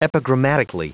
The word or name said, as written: epigrammatically